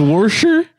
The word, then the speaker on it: Worcester: There's a town that's spelled Worcester. – Is it say? – Worcester. – They have to do Worcestershire. No. Or some bullshit like that. No. Is Worcester? – Yeah, like that.